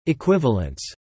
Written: equivalents

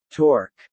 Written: torque

